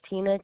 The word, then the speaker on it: Tina: It's Francisco and Tina Hernandez. – Spell that for me. – Francisco, C-I-S-C-O. My name is Tina, T-I-N-A. Last name Hernandez, H-E-R-N-A-N-D-E-Z. – Thank you. That's going to be F-R-A-N-C-I-S-C-O and Tina, T-I-N-A.